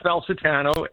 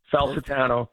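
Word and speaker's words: Falcetano: Okay, my name is William Falsetano, and it's spelled F as in Frank, A-L-C-E-T-A-N-O, Falsetano.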